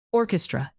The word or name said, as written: orchestra